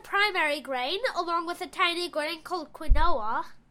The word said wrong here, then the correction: quinoa: You mean quinoa. – Yeah. – Quinoa. So, in English, it's spelled Q-U-I-N-O-A, but it's actually pronounced quinoa.